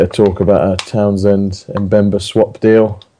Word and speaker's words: Mbemba: I know there's a bit of talk today on Sky Sports about, I'll pronounce it for you because I know you struggled earlier, Chancel Mbemba. – Mbemba at Newcastle.